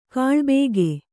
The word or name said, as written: ಕೂಳಬಾಕ